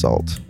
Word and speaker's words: SXLT: If you want to check out some of me and my business partner work on our apparel brand, it's called Salt, and that is SXLT, pronounced Salt.